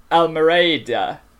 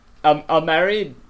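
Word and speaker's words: Almereyda: It's Michael Almereda. Almereda.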